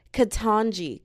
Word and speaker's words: Ketanji: Before I even rant about the Supreme Court, I just want to say not everyone, because Miss Ke- is it pronounced Kanja Kenji? Kanja Jackson, the first black woman to become a U.S. Supreme Court literally, let me see, let me. Con. Conja Jackson. I'm making sure. Ketanji, Ketanji Jackson. I wanted to make sure I had her name right because I'm like, there's no way I'm about to butcher this girl's name.